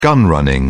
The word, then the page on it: gun running: British English